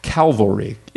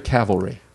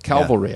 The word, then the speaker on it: Calvary: And it's pronounced Calvary. Is it Calvary or Cavalry? – I've heard Cavalry. – Calvary. Yeah. I'm having trouble pronouncing it. – Because it's Calvinism, like, but Calvary.